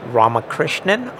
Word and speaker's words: Ramakrishnan: Krish, K-R-I-S-H, Ramakrishnan, R-A-M-A-K-R-I-S-H-N-A-N.